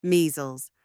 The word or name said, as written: measles